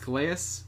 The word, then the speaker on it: Calais: One of those is the player whose name I learned to pronounce recently, Calais Campbell. It's spelled like Kalai, but I read an interview. He says he pronounced the S. I believe it's Calais.